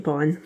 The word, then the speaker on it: Biehn: Biehn, I always forget how we say his name. Michael Chalmers again. – I think Biehn.